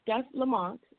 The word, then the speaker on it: Steph Lamonte: Here it is, Steph Lamont. I want to make sure I say that right.